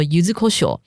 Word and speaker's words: 柚子胡椒: 对，所以因为九州发祥的关系，然后因为九州好对于糖心子的认知，好把它认知成南蛮胡椒，因此呢就变成了柚子胡椒，好念作 u z u k o s h o 那事实上，你若去九州看的话，有的是会写 u z u k o s h o 就是那个 ko，有的会变浊音锅。